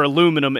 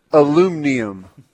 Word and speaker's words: aluminum: Aluminum is the right way to say it around here.